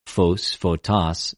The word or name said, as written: φως,φωτος